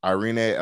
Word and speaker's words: Irene: Amanda Nunes, Irene Aldana. I think she pronounces the E at the end of Irene. Mm. I don't think it's just Irene.